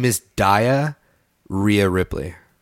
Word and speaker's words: Rhea: Rhea Ripley? – Rhea Ripley.